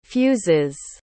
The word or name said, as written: fuzes